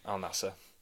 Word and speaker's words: Al Nassr: Is that Al Nassr? Is that how you say it? – That is how you say it, but it's not our team. Al Nassr.